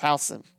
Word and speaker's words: Houston: You could be talking about the city in Texas, or you could be talking about the small school, small village in Ohio that's pronounced Housen.